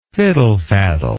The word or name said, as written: fiddlefaddle